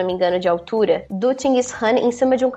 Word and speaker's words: Chinggis Khan: Aqui, a pronúncia local seria Chinggis Khan. Chinggis Khan.